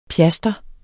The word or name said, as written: pjaster